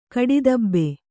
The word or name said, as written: ಕಡಿದಬ್ಬೆ